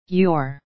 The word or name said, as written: your